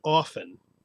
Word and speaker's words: often: We got an email from Lindsay in Boston asking, is it wrong to pronounce the T in often? – Aha. – Or is it just a regional thing and it's okay? And we're going to ask you the question. Let's see you answer, Lindsay, and then we'll discuss it. – I mean, wrong. You know, I guess probably not wrong. I think it is supposed, in quotation marks, supposed to be pronounced without the T.